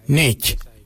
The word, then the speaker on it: Ngei: Now let's take that out, D-J-I-L, and let's put another word, which is Y-A-N-G, Nganyang. Nganyang means give me. For I, we say Nech, Nech. It's spelled N-G-E-I.